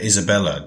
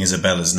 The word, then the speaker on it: Isabella: I assumed that it was the same in Spanish, and that it must be pronounced Isabella, not Isabella. It turns out that this was incorrect, so sorry about that.